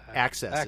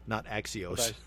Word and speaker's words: AXS: That's refer the AXS is how they pronounce it, not Axios.